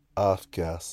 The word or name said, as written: aaf kaas